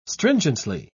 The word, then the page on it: stringently: British English